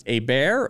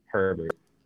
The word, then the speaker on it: Herbert: Is his last name pronounced A Bear or Herbert? – Herbert.